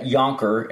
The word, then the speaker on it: Yankauer: Going back to that Yonker, and it's spelled Yankauer.